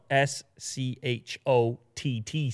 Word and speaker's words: Schott: It's another company, a German company called Schott, but not spelled the way you expect it to be spelled. – Mm. – Spelled S C H O T T, Schott.